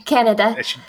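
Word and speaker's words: Canida: Christy Kaneda or Kaneda. I don't know how to say her name. – Canada. – They didn't say it. – Yeah. – Is it really Canada? Oh, damn it. It's like my country.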